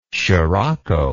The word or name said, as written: scirocco